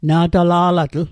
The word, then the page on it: na'tala'latl: Mi'gmaq